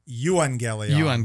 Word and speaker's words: euangelion: It finds its origins in the Greek, and the word evangelion. Am I pronouncing that right? – I think Doug says euangelion. But I'll go with either way.